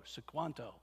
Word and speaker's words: Squanto: That's spelled S-Q-U-A-N-T-O, Sequanto.